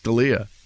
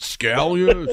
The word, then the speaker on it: Scalia: I don't feel bad when they mispronounce Scalia. They go, is it Scalia? Scalia? I'm like, just pick one.